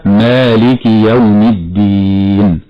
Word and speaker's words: مالك: نعم، قلت أنه خطأ لأن الكاف هنا مكسورة هكذا مالك يوم الدين.